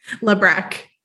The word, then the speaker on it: Labrecque: Labrecque.